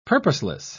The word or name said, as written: purposeless